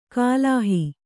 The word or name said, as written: ಗ್ಲಹ